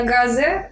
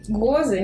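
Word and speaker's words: Gose: Кстати, правильное произношение слова с ударением на букву О. Гозы.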